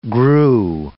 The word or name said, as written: grew